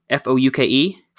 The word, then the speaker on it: Fouke: Then we drove over to, I think I'm saying it right because I asked when we were there, Fouke, F O U K E, Fouke, I believe, which is like 15 miles outside of Texarkana in Arkansas, home to the Boggy Creek Monster.